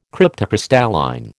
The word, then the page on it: cryptocrystalline: American English